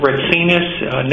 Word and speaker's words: Retsinas: Nicholas Ritsinas. Nicholas is without an H, and Ritsinas is R-E-T-S-I-N-A-S.